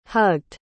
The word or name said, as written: hugged